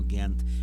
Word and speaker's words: Ghent: así se escribe Loeillet, y va a aparecerles la partícula de Gante o de Ghent, según aparezca citado, ¿no? Ghent por la pronunciación en flamenco.